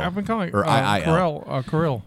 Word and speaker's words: Kuiil: Let's talk about dead Kweel. – Kweel? – Kweel? – Kweel? – Kweel? – Kweel? – Kweel? – Kweel? Kweel? – Is there a silent Q? – It's actually K-U-I-I-L.